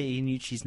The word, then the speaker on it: Iannucci: How do you say Iannucci's name? – Iannucci. – Armando Iannucci.